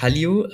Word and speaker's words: Hallyu: genau, also einfach Hallyu.